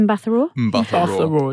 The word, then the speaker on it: Mbatha-Raw: The one with Gugu Mbatha-Raw, is that how you say it? – Mbatha-Raw, yeah.